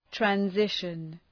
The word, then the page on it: transition: træn’zıʃən